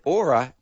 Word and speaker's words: Oura: Written, yeah, but it's spelled O-U-R-A, but the wonderful people who have written the ad have said Aura as in Flora, just in case I don't know how to pronounce it.